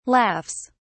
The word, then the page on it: laughs: British English